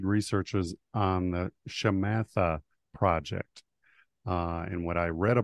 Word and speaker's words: Shamatha: So this, it's called, it's the way to pronounce it is Shamatha.